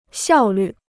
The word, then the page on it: 效率: xiào lǜ